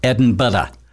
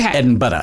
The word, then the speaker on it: Edinburgh: when you're over there, you have to pronounce it Edinburgh. – Okay. – Edinburgh. It actually has a second syllable. – It almost sounds like bread and butter.